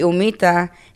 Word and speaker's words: humita: So the humita, the H is silent for that. – Right. – So you don't even say the H.